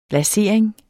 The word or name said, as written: lasering